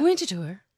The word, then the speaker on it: Winterthur: And you have to say it like that. – Yeah. Winterthur.